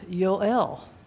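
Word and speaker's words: Yoel (יוֹאֵל): In the Hebrew, it's pronounced Yoel.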